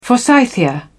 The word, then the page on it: forsythia: British English